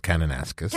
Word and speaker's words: Kananaskis: It's pronounced Kananaskis.